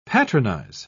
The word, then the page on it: patronise: British English